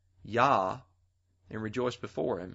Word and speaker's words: Jah: And the KJV will spell it J-A-H. But back then, the J was pronounced as a Y, just like in the word Hallelujah. Hallelujah is spelled at the end of the word J-A-H, but it's pronounced with a Y sound. If you have a New King James Version, Psalm 68:4 will actually read Y-A-H.